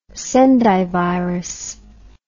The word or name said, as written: Sendai virus